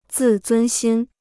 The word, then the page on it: 自尊心: zì zūn xīn